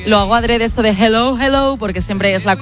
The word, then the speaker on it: hello: Y la segunda, dile a Elena que su dicción en castellano es la más perfecta que conozco, pero que cuando diga hello, que no pronuncie la J, que no es hello, que es hello, que la aspire la J y no pronuncie la J potente castellana.